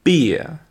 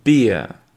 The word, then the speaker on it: beer: Vous avez ensuite une bière qui se prononce beer, beer. Donc je répète les deux, bear, beer.